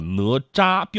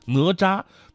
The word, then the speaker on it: 哪吒: 发现字典里边应该叫哪吒，标准的一声哪吒。但是放在口语里边，你讲话的话很很很很怪。昨天你看哪吒了吗？